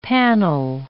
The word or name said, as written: panel